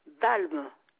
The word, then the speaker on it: Balme: Balme Suzanne. – Balme, cela s'écrit comment, s'il vous plaît? – B-B-A-L-M-E. Balme. – Comme ça se prononce, alors. – Voilà, voilà.